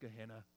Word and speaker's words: Gehenna: The word that's used here, it's pronounced Gehenna.